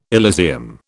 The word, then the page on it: Elysium: British English